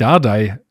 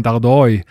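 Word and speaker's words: Dárdai: Ähm, und dann ist ja auch bekannt, macht aber keiner in Berlin, äh, ähm, dass Dardai nicht Dardai äh, eigentlich ausgesprochen wird, sondern Dardoi. Und auch Palko wird anscheinend nicht Palko ausgesprochen, sondern Bolko. Bolko Dardoi.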